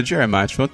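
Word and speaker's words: Diarmaid: O Dermot é Diarmid, tudo, tudo brasileirado.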